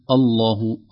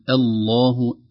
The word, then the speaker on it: الله: نلاحظ فيه أن الهمزة في أوله منطوقة، هي همزة وصل، لكن إذا بدأنا بها تنطق وتصبح كأنها همزة قطع، لكن بعدها لام لفظ الجلالة المغلظة. لا نقول الله نقول الله الله الذي أنزل الكتاب، أي نرقق الهمزة ونغلظ اللام من لفظ الجلالة.